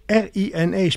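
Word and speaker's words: RI&E: RINE, spreek je het zo uit? – Ja, zeker. Ja.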